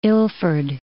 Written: Ilford

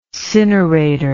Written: cineration

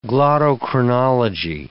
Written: glottochronology

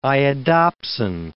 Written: iodopsin

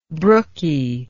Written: brooky

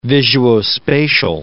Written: visuospatial

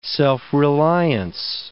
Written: self-reliance

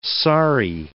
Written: saree

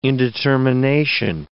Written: indetermination

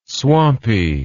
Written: swampy

